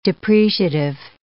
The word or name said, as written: depreciative